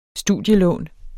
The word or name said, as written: studielån